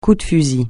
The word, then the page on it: coup de fusil: French